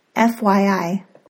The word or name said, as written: FYI